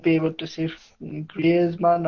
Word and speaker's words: Griezmann: And they had to offload Griezmann as well.